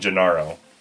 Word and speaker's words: Gennaro: So again, if you go to Italy, it'd be, where is San Gennaro? And they spell it G-E-N-N-A-R-O.